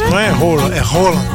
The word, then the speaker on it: Haaland: Pronúncia do nome Haaland não é desse jeito não. No país dele é rola, eita que situação.